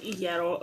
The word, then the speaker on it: Iero: It's Iero. Okay, it's not Iero or Iero.